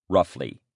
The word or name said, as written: roughly